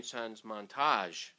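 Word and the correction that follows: TransMontaigne: Transmontage. – Yeah. – Transmontane.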